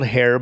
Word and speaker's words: H31R: All right, so the last thing on your list is a duo, and it's called Hair, but it's spelled H31R.